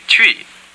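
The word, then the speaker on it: Twi: Say, I speak Tree. – Miti Tree. – It may help you to pronounce the word Tree if you think of it as though it were spelled C-H-R-E-E with an American R sound. C-H-R-E-E.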